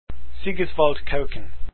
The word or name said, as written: kuijken